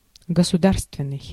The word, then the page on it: national: /ˈnæʃ(ə)nəl/